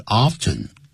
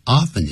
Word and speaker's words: often: 刚才你念的是 often, 两个发音都可以 ,often 也可以跟 often, 两个发音都可以。